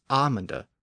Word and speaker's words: amande: I should note that the modern French version of the word is amande without the L, but in older French, both pronunciations existed.